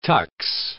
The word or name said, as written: tux